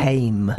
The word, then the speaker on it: Haim: Haim.